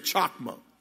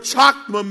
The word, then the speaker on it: chokmah: Now, y'all remember when we first started this, I gave you a Hebrew word, and it's called, that word is spelled C-H-O-K-M-A-H. Everybody say chokma.